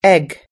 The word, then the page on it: egg: us-english